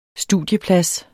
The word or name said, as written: studieplads